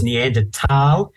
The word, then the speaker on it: Neanderthal: apparently the correct pronunciation is Neanderthal. Thal actually means, it's a German word for valley.